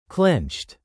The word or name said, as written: clinched